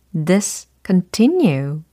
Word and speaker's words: discontinue: discontinue. 이렇게 되는 거죠. discontinue, discontinue, discontinue. 좋습니다. discontinue가 아니고 discontinue, discontinue. 이와 같이 발음을 해주시면 되겠습니다.